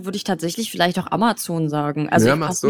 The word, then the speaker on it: Amazon: Da steht tatsächlich Amazon. Man soll Amazon sagen.